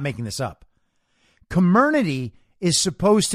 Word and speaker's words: Comirnaty: It's called Comirnaty, right? Apparently, that's the right way to pronounce the new brand name of the BioNTech Pfizer-like vaccine, right?